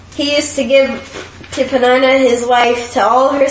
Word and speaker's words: Peninnah: So blue letter Bible, we'll show you how to pronounce it. So it's Peninnah.